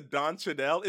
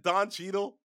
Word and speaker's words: Don Cheadle: I think I just pulled a Kevin Hart and Don Shadell, where he's like, damn. – Hey man, I gotta say, I love the way you're pronouncing these words. That was a Don Cheadle, isn't it? Don Cheadle.